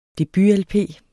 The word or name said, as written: debut-lp